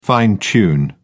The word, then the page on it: fine-tune: British English